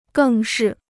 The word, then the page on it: 更是: gèng shì